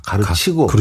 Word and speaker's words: 가르치고: 제대로 아, 그이 후배들 엄격하게 가르키고 가르키고가 아닙니다. 가르치고.